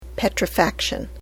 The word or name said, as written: petrifaction